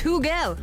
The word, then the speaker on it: Hugel: Some are saying it's Huggle. Some are saying it's Hugel. Apparently, it's Hugel.